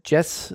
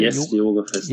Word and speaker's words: Yes Yoga Festival: Und abschließend dann nochmal so das, äh, wie spricht sich's aus? Jazz oder Yes Festival? – Yes Yoga Festival.